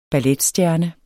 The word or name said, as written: balletstjerne